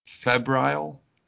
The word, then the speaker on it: febrile: And that was febrile seizure.